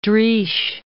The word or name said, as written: Driesch